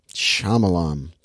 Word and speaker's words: Shyamalan: Is it Shyamalan? I'm looking at it right now. Have I been saying his name wrong this entire time? – It's Shyamalan.